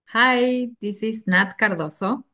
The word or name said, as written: Nat Cardozo